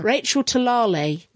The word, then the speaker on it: Talalay: Ah, now there's a way of pronouncing Talalay. – This. – Talalay. – No, it's not. – Talalay. – She went on Twitter. It's not Talalay to rhyme with Gallifrey. It's like Tulalay or something like that.